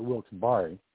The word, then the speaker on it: Wilkes-Barre: It's Wilkes-Barre. – Fabian used a short O-A sound, so he called it Wilkes-Barre.